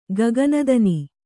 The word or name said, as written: ಗಗನಧುನಿ